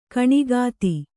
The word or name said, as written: ಕಣ್ಗೆತ್ತು